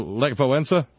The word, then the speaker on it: Lech Wałęsa: Take Lefrawenka. Am I saying that right? Lefrawenka? – Lech Wałęsa? – Yes.